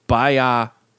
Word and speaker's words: biopic: Some people say biopic. – Then they need to say biopic. Like, you got to put that space. – The right emphasis on the right syllable.